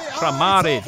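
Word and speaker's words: Kramarić: Kramaric, which I must admit, I didn't know that's how you pronounce that.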